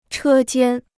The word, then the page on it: 车间: chē jiān